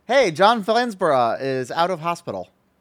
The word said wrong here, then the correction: Flansburgh: Oh, Flansburgh.